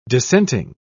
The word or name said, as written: dissenting